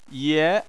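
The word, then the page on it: ye: tone 2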